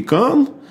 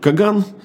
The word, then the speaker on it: каан: Вот, ну, на самом деле, это правильнее произносить даже не Каган, а Великий Каан, но, так сказать, в русской транскрипции утвердилось Каган, вот.